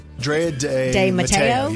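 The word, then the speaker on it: Drea de Matteo: Drea DeMatteo, yeah.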